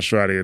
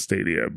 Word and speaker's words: Stadia: So it's not Stradia, it's Stadia. But yeah, I mean, he says that they should be paying for licenses.